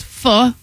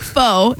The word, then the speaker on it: pho: I can tell you one of the best pho places. That's how you say it. It's not pho. It's right. – Pho.